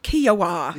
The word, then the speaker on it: Kiawah: How do you spell it? – K-I-A-W-A-H. Kiowa.